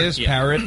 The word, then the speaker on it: disparate: Is that how you pronounce it? Here. Disparate? Yeah.